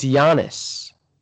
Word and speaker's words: Giannis: How do you pronounce it? – It's Giannis.